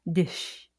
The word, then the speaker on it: DIHS: Do you pronounce it DIS or DISH? – I think I usually put the H in there, but it's sort of like an afterthought, like DISH. It's like a soft sh.